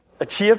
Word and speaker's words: Achaia: I can never figure this one out, Achaia, A-C-H-A-I-A, somebody, linguistics specialist, how would you say it? Achaia? Achaia pet? See, that's where my mind goes, I'm sorry. Achaia works, that's better, yeah.